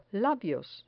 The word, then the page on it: labios: spanish